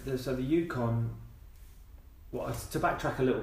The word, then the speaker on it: Yukon: Like the, is it the is it pronounced it the Yukon Yukon Yukon, Yukon, River, Yukon River yeah.